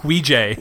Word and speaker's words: Ouija: Ouija. – Yeah. That's how it's pronounced. – Ouija. We found it. You just had a Y Kiesto at the end of it. – I don't know. It's just a long A.